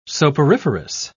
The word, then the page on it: soporiferous: British English